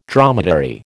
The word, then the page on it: dromedary: American English